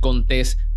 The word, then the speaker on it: Contés: Mi nombre es Miguel Contés con acento en la E